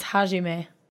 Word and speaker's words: Hajime: It's Hajime.